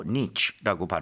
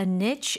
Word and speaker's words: niche: 자, 그리고 niche라고도 발음할 수 있고 niche라고 발음할 수도 있는 거 바로 틈새, 특정 영역을 얘기를 하죠. – The brand carved out a niche in the luxury skincare market.